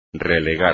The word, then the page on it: relegar: espagnol